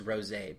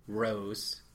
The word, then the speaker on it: rosé: And I'm sorry for pronouncing it rose because it was rosé.